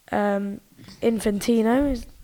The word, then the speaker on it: Infantino: Obviously, Infantino, that's how you pronounce it, I'm pretty sure.